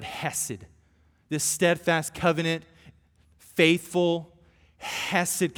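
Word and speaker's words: hesed (חסד): My Hebrew professor used to say, if you're not spitting on people when you say the word hesed, you're not saying it right.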